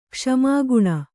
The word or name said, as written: ಕ್ಷಮಾಗುಣ